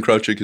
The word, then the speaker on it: Krawczyk: K-A-T-I-E. – Second name. – K-R-A-W-C-Y... I'm sorry. K-R-A-W-C-Z-Y-K. – Krawczyk. – Krawczyk. Yeah, I think you're right. Krawczyk. – It's Polish, I think. – Yeah. You don't both have to assume that the name Krawczyk is Polish.